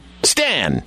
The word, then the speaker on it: Stan: S-T-A- N. Stan. – Hello, Satan. – That's Stan. It's S-T-A-N.